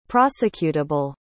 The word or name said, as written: prosecutable